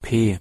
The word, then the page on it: p: German